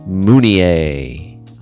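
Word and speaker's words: Mounié: Steve's last name has an acute accent, which is why you pronounce his name Steve Mounier.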